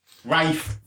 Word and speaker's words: Ralph: If he wants to pronounce Rafe... – You're an inanimate fucking object! – We've all got fucking suicidal thoughts. I'm fucking suicidal. – You take that back about my camp kids. – If he wants me to pronounce his name Rafe, he can spell it fucking Rafe. But when he starts spelling it Ralph, A-R-L-P-H, no.